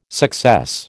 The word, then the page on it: success: American English